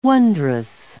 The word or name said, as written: wondrous